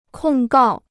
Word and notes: 控告: kòng gào

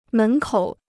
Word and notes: 门口: mén kǒu

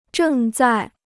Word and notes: 正在: zhèng zài

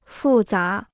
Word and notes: 复杂: fù zá

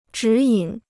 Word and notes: 指引: zhǐ yǐn

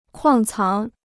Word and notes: 矿藏: kuàng cáng

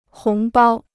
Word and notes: 红包: hóng bāo

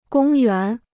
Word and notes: 公园: gōng yuán

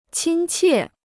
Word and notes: 亲切: qīn qiè

